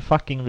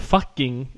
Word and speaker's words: Fucking: Så byn Fucking vill gå till folkomröstning om att byta namn. – Mm. Och eh, det uttalas ju såklart inte fucking, utan det uttalas Nej, det på fucking. på österrikiska då.